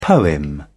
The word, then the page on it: poem: British English